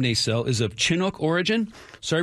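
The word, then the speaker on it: Naselle: The pronunciation guide published by WSU in the 1960s puts the emphasis on the second syllable, Nacelle. – Yeah. – But when I called about six different businesses there yesterday, the consensus was the opposite.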